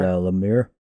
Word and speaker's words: Lemire: Lemire, is that how you say it? Lemire? – Lemire.